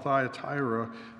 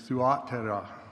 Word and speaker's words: Thyatira: As I mentioned, the name of this city is often called Thyatira, but the actual pronunciation of its name is Thuatira.